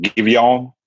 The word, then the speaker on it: Giveon: It's uh, I think you pronounce his name, Giveon, uh, G I V E O N. Uh, uh, that is correct.